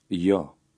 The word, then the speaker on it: jo: In Albanian, the letter J is pronounced like the letter Y in the English word you. Try to read number 4. – Jo.